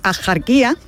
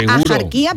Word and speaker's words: Axarquía: Y aunque hoy la RAE deja claro, la RAE dice que lo culto y correcto es pronunciarlo con J, es decir, Axarquía, la tradición local sigue pronunciando y no solo escribiendo Axarquía o Axarquía, ¿no?